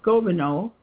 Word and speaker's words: Gobineau: Now, the next person that I can mention here is Arthur de Goethe. I didn't check out how to pronounce his name. I just think I know, but I might not know. Gobineau, Arthur de Gobineau, who's known for his racial theories, and he was French, though.